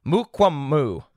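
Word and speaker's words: Mukuamu: South Carolina's Izzy, it's Israel, but Izzy Mukwamu. I want to make sure I got that right. I looked up the pronunciation guide.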